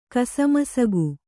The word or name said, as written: ಕಸಮಸಗು